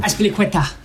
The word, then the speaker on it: Azpilicueta: Azpilicueta, right? Is that how you say it? Azpilicueta. Fuck's sake. Well, he's got the hardest name to pronounce. I don't know. – Azpilicueta. – Yeah, there you go.